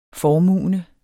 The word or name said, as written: formuende